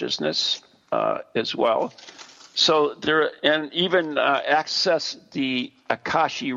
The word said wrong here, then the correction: Akashic: records. Oh my God, I said it right. Did I say that right? – Akashic. – Akashic.